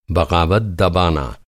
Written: बग़ावत

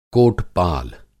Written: कोट-पाल

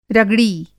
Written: रगड़ी

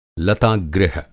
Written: लतागृह

